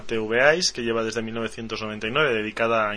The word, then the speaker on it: TVEyes: O TVEyes sería la o sea, la pronunciación correcta.